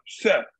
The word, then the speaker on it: Seth: But, Did you say his name was um, Shep? – Seth S E T H. Oh, Seth.